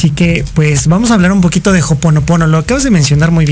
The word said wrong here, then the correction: Ho'oponopono: Sí, se pronuncia Ho'oponopono.